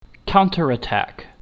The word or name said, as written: counterattack